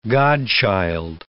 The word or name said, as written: godchild